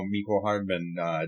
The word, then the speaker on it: Mecole Hardman: If you send, you know, Mecole Hardman, Tyreek Hill, Travis Kelce up the field, I like their chances. Yeah. I like their chances to convert that play. – Thank you for pronouncing Mecole Hardman correctly.